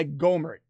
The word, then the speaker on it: Gohmert: But Yeah, Gohmert is how you pronounce it, but I've always pronounced it Gohmert. He even made fun of me. Last time I saw him, I had a coffee with him. He said, you know, you never pronounce my name right, but it's okay.